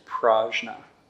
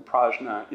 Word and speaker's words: prajna: The word for that, again, is prajna. There's another Sanskrit word for knowledge, jnana. The second syllable in prajna is jna, prajna.